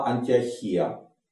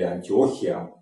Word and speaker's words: Антиохия: А на побережье находится ключевой город в Сирии тогдашний, это Антиохия э, или Антиохия, по-разному ударения ставят.